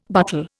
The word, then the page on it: butle: Indian